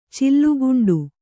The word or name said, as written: ಚಿಲ್ಲುಗುಂಡು